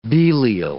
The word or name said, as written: Belial